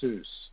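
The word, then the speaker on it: Iesus (Ἰησοῦς): So in Greek, Jesus' name is Iesus. So there's a long E sound in the beginning of the Y. It's Iesus.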